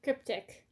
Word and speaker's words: Cryptic: The Cryptids or Cryptids. – Cryptic. – Cryptic. And it, uh, it's spelled C R Y P T I C C.